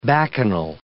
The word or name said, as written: bacchanal